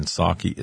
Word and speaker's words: Psaki: With respect to Biden and Jen Psaki, the press secretary, remember the P in Psaki is silent, just like the P in president.